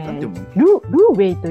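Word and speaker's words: 滷味: ああ、はいはい、これね、これね、ルーウェイと読むらしいですよ。 – そうです、中国語の発音で読むのね。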